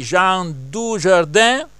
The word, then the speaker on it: Jean Dujardin: Gli attori, vedo qui Jean Dujardin, se pronuncio correttamente, sì.